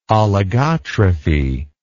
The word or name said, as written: oligotrophy